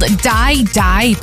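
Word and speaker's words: Dai Dai: It's called Die Die, but that's spelled D-A-I rather than D-I-E, and it's her latest collaboration with FIFA after she released Waka Waka for the 2010 World Cup in South Africa.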